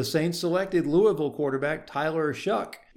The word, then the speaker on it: Shough: Tyler Shough, whose actual name is spelled S-H-O-U-G-H, but pronounced Shuck, played for three different college football teams over a six-year period.